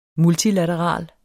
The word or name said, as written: MULTILATERALT